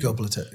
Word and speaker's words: Göbekli: Göbekli.